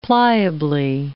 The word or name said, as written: pliably